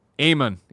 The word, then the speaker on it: Amon: Amon in D.C. rhymes with Damon.